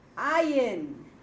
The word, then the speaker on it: Ayin: Ayin, another silent letter like aleph. Shh, it's very quiet. It will only say something when you put a vowel pointing.